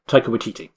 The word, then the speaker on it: Taika Waititi: Taika Waititi, thank you.